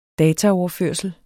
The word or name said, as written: dataprogram